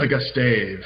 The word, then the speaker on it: Augustave: But I saw a guy like, and I hope I'm saying his name right, Isaiah Augustov. Is that how you say his name? I mean, he's always a trick. I think it's Augustave. – Augustave, okay, because I've heard it about three different ways.